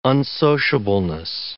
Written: unsociableness

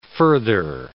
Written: further

